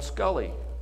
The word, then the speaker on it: Sculley: He approached John Sculley, which rhymes with Tully.